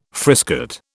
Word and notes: frisket: British English

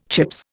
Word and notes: Chips: Indian

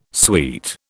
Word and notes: suite: British English

